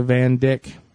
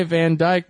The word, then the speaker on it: Van Dyck: Because it's a Van Dyck painting. – Van Dyck? – It's a Van Dyck painting. I would say Van Dyke, but it's spelled D-Y-C-K.